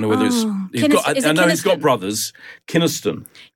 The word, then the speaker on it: Kynaston: Please note that Laurie Kynaston, as I said it was, is actually pronounced Laurie Kynaston.